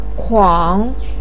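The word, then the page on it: kuang: tone 3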